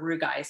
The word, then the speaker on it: rugae: And if you, so within our, the walls of our vagina, we have what's called rugae or rugae, some people pronounce it.